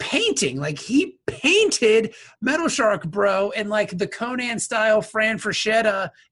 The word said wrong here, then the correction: Frazetta: Frank Frazetta.